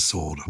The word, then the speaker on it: sword: It's a Germanic word, and that explains the silent W um, because it's S in German, it's S C H W E R T. Of course, we don't say sword, do we?